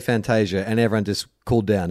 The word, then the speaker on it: Fantasia: So look, here's my understanding is that it came out that the actual pronunciation was Fantasia, and then BT has come out and said, yeah, but you Australianize it and make it Fantasia.